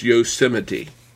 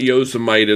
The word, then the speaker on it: Yosemite: And we're about to go 10-10 here this fall with Yosemite, and that is how you pronounce it, folks. It is Yosemite, not Yosemite, as I've heard some people say.